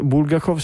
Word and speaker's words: Bulgakov: E, um, Chat GPT dice che il cognome Bulka, Bulgakov si pronuncia con l'accento sulla seconda sillaba, per cui dà ragione a te Michele.